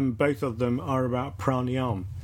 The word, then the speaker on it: pranayama: Pranayama. – When it's the A on the end is silent. – If pranayama. Pranayama. – If you go into classical Sanskrit, the A's on the end are silent.